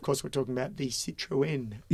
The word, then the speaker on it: Citroën: So kudos to them, Citroën. – And then kudos to you to do the right pronunciation.